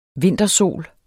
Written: vintersol

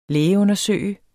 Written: lægeundersøge

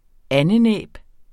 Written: andenæb

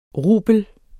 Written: rubel

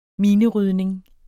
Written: minerydning

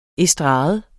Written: estrade

